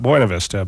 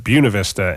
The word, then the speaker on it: Buena Vista: Not Buena Vista, but Buena Vista.